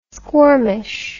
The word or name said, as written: squirmish